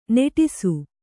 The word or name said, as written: ನಟಿಸು